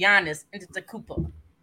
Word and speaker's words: Antetokounmpo: We're going to start with the first one, a healthy Anthony Davis at his best, or a Giannis Antetokounmpo.